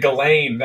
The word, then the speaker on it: Ghislaine: Oh, man, Ghislaine, that's good. – Is that how you pronounce it? – Yeah, it's G. – So the S is silent.